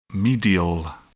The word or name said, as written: medial